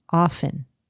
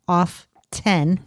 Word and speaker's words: often: It is often. – What? There's a T in there. – Yep. And listen to this. It says, if you're saying it as off 10. – Oh, God. – You're technically wrong. The dictionary prefers the T-less version since it better reflects the evolution from its Middle English roots.